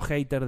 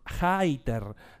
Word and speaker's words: Hayter: No Hayter de odiador, no. Es Hayter. Se escribe así.